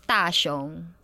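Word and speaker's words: Da Xiong (大雄): So with the character names, we just go by whatever kanji they use, how that particular kanji is pronounced in Chinese. So Nomida would be one of the kanjis, and in Chinese, we pronounce it Xiong.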